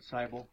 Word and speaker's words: Seibel: Madam Chairman, my name is Conrad Seibel. Last name is spelled S-E-I-B-E-L.